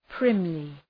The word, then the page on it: primly: ‘prımlı